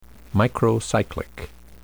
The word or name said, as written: microcyclic